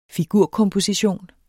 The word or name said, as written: figurkomposition